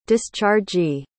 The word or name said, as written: dischargee